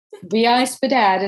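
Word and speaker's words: VI-SPDAT: She can pronounce it. – I read it. I couldn't pronounce it. – VI Spadat is how it goes.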